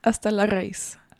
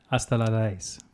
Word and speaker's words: Hasta la raíz: And how do you pronounce the song itself? – Hasta la raíz. – Hasta la raíz.